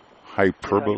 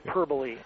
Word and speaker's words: hyperbole: Henry, that word is hyperbole. – Hyperbole. – How you pronounce it? – Okay. – Hyperbole. And what? Don't worry about it. Anyway, let's make sure it's here. It's hyperbole.